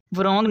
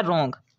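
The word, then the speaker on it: wrong: इसके बाद है रोंग, वरोंग नहीं पढ़ना, ये होता है रोंग।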